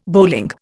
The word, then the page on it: bowling: Indian